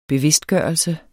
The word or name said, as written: bevidstgørelse